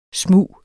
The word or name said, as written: smug